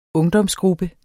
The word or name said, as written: ungdomsgruppe